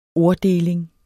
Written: orddeling

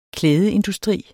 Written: klædeindustri

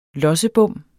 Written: lossebom

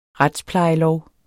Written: retsplejelov

